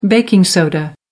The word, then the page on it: baking soda: American English